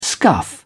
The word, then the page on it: scuff: British English